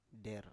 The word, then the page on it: der: Biak